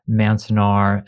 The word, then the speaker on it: Manzanar: And you focus in particular on Manzanar and Poston.